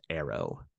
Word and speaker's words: ARO: You also see the emergence of augmented reality, which allows virtual icons to overlay physical objects and locations, called augmented reality objects, or ARO, pronounced arrow.